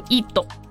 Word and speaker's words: ID: 那么首先呢，这个 ID 这个呃词或者说 ID 这两个英文字母呢，在日文里面的发音叫做 ido，英文如果说读出来也是 it，但是在日文里面就是 ido。